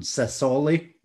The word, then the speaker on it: Sassuolo: On Sassuolo? – Yeah.